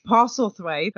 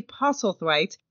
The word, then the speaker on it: Postlethwaite: Down Deep Deputy Hank, a.k.a., and I'm going to see if I can get the name right this time, Billy Postlethwaite? – Postlethwaite. – Okay, Billy Postlethwaite.